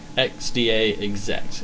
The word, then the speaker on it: XDA Exec: XDA exec. – XDA exec. – My exec new for, like, executive.